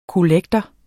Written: kollektor

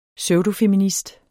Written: pseudofeminist

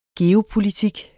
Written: geopolitik

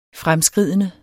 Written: fremskridende

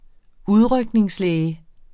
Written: udrykningslæge

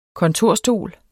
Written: kontorstol

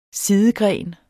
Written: sidegren

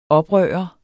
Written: oprørt

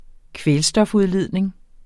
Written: kvælstofudledning

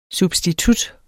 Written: substitut

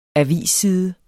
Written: avisside

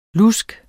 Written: lusk

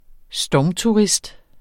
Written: stormturist

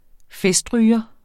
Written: festryger

